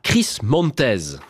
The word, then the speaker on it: Chris Montez: The More I See You, la version de 1966, chantée par Chris Montez.